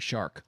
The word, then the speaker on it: Shark: Oh, by the way, this apparently, if you look at his pronunciation, it's supposed to be Shark, like the animal.